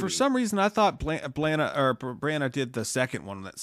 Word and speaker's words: Branagh: The other one that I would pick, and he's already under the MCU umbrella, is Kenneth Branagh. I don't know if I'm saying it right. – Branagh.